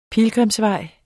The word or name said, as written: pilgrimsvej